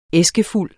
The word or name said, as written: æskefuld